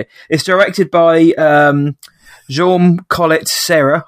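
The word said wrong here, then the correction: Jaume Collet-Serra: I've never known how to say his name, but Jaume Colette Serra.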